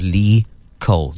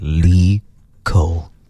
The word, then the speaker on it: Lee Cole: His name is Lee Cole. You have to say it like that. You have to say it smooth like that. Lee Cole.